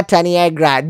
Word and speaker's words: Taniegra: Let's all welcome our very special guest for today's episode, the very beautiful, very amazing Anna Taniegra.